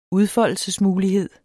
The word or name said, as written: udfoldelsesmulighed